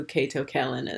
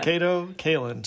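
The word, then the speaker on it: Kato Kaelin: The other thing that is a positive about this is that I have no idea who Cato Kaelin is. – Yeah, you didn't even say his name right. So, Yeah, clearly, no idea. – Cato Kaelin.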